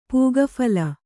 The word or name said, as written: ಪೂಗಫಲ